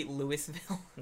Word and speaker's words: Louisville: He lives in Louisville, Kentucky. Yeah. Turns out that's the proper pronunciation. – It's actually Louisville. – Yeah. Louisville. – Sorry, no, Louisville. No.